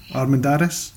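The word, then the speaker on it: Armendáriz: Pedro how would you say his surname then? Armendariz? – Yeah, I think that sounds about right.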